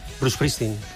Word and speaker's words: Springsteen: Bruce Springsteen. – ¿Bruce Springsteen? – Bruce Springsteen. – Sí, bueno, nosotros es que la S... La, claro. – Pronunciamos la E delante, ¿no? – Claro, decimos Bruce Springsteen.